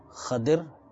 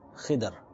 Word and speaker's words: Khidr: Ulama Ikram have said that it can be read as Khadir, it can be read as Khadr, and it can be read as Khidr.